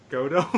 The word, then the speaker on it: Gadot: We used to say Godot. I've been hearing people pronouncing the T lately on, like, TV shows. She says you have, like, the T is not silent.